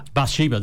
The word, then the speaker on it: Bathsheba: you, Bathsheba Beach, is that right? Is that correct? – Bathsheba, there you go. Yeah, nothing like the tongue of a native person to get the right pronunciation.